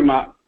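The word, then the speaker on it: Mauch: I just feel like, you know, Cody is in Mach. I always say his name wrong. – Yeah, no, Cody Mock.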